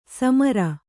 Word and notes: ಸಮರ: samara

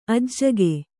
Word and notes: ಅಜ್ಜುಗ: ajjuga

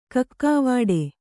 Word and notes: ಕಕ್ಕಾವಾಡೆ: kakkāvāḍe

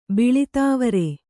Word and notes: ಬಿಳಿದಾವರೆ: kannada

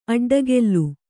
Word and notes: ಅಡ್ಡಗೆಲ್ಲು: aḍḍagellu

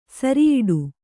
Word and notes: ಸಿರಿಯಡಿ: siriyaḍi

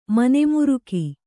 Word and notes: ಮನೆಮುರುಕಿ: mane muruki

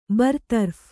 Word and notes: ಬರತರಫ್: kannada; barataraph

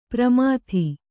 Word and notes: ಪ್ರಮಾಥಿ: kannada

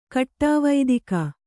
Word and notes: ಕಟ್ಟವತ್ತಿಗೆ: kaṭṭavattige

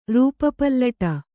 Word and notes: ರೂಪಪಲ್ಲಟ: Kannada

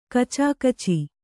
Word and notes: ಕುಚುಕುಚು: kucukucu

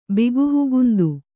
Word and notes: ಬಿಗುಹುಗುಂದು: bihugugundu

